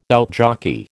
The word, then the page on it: outjockey: American English